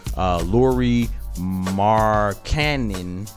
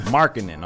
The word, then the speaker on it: Lauri Markkanen: And then we had the number 7 pick, I believe it's pronounced Lauri Markkanen. – And Lauri Markkanen. – Markkanen, my fault.